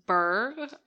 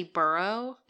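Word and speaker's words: Burgh: Um, at age 17 in 1529, she marries Sir Edward Burr, or possibly Burrow. It, th- there are two pronunciations.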